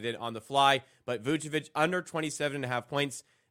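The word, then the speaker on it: Vučević: For Nikola Vucevic, 15, or Vucevic, sorry, I'll say it correctly for Pips here, 15-plus points and 10-plus rebounds.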